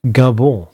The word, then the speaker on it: Gabon: Nästa afrikanska land till rakning är Gabon, eller kanske Gabon, som man kanske säger på med ett mer sofistikerat uttal.